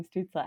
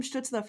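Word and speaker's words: Stützle: Like if we just like do a German accent for Tim Stutzle, I don't know how to say his name in a German accent, but like I think that's the move going on.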